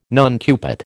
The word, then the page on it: nuncupate: American English